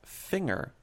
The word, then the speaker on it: Finger: Which also, my last name is technically supposed to be pronounced Finger with a soft G.